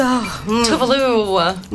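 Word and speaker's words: Tove Lo: So that was Talking Body by Tuvalu right here on Y101. So Tuvalu.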